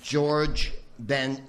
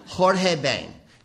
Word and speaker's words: Jorge Ben: In Portuguese, the J letter sounds closer to the way you pronounce it in English than to Spanish, by the way. I called Georges Ben... Does anyone speak Portuguese? Thank you. Jorge Ben. I thought it was Georges Ben.